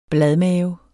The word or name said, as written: bladmave